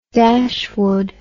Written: Dashwood

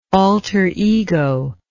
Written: alterego

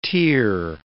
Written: tier

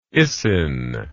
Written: Isin

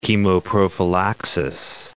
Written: chemoprophylaxis

